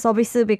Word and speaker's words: 서비스: And I really liked how you pronounce 서비스 because for English-speaking people, it might feel a little weird to make it sound like Korean pronunciation, service to 서비스, but you did a really good job.